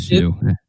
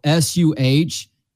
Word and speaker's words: Suh: I think you're crazy, and I love how you were able to pronounce Ndamukong correctly, which is like ten letters, but the three letter last name is the one that tripped you up with Sue. It, dude, I am a I am very phonetic with how I read things, and I need to I need to go out of my way to mispronounce it if it's a weird pronunciation or if it's a weird spelling. And every time I see Sue S U H, it looks like Suh.